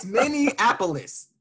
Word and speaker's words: Minneapolis: It's Minneapolis. – Yes, Minneapolis. It's not hard. How many syllables? Like it's five syllables. It's five syllables, people. Minneapolis.